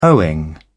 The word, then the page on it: owing: British English